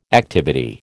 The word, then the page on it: activity: American English